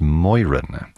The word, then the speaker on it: Moeran: Moran, although I've heard his last name pronounced Moiran, but M-O-E-R-A-N.